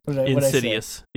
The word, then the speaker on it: insidious: It's Insiduous, and that is the scariest part of it all, really. So, did I say it wrong, Josh? Is it Insidious? – Insidious, Okay. Insiduous I see Josh going to type now, and then I know he's going to be funny.